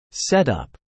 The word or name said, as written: setup